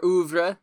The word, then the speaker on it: oeuvre: That's how you pronounce that? Our oeuvre. – Or oeuvre, yes. – Which is how you pronounce that, definitely, for sure.